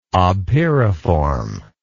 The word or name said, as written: obpyriform